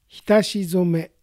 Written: Hitashizome

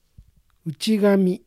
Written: Uchigami

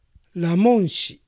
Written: Ramonshi

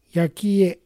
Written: Yakie

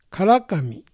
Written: Karakami

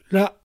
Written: Ra

